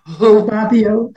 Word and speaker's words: Ababio: And Marilyn Ababio. Did I say that right? – Ababio. – Ababio. Okay.